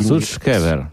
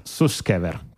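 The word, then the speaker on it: Sutskever: e il primo è quello di Ia Sushkever Suskever. – Suskever. – Suskever. Quindi io vado avanti con fiducia a pronunciarlo così.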